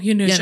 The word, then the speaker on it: Janusz: Mm, do you like Harush? – I love... – Is that you say his name? – Janusz. – Oh, Janusz. I'm sorry.